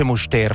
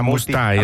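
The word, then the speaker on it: Müstair: Sono a Disentis e poi io ho sempre detto Disentis Muster nella mia vita, ma mi hanno appena corretto. Qua non si dice Muster, fra un po' vi dico esattamente come si dice. – Mustair.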